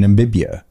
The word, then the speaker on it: Namibia: Namibia has shown up. We're number 4 in Namibia for popular.